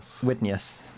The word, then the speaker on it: Widenius: Widenius, that would be how you pronounce that.